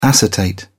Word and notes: acetate: British English

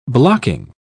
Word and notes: blocking: British English

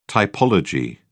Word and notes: typology: British English

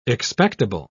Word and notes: expectable: British English

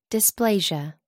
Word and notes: dysplasia: American English

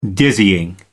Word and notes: dizzying: American English

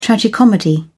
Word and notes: tragicomedy: British English

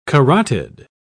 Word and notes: carotid: British English

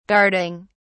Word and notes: guarding: British English

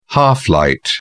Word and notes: half light: British English

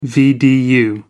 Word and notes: vdu: American English

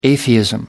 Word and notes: atheism: British English

